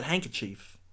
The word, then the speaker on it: handkerchief: Listening people, always remember, the word handkerchief has a D in it. – Oh, you didn't know that? – I messed up, Ryan. Because I never pronounced the D. I always pronounce it handkerchief.